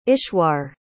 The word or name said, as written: ishwar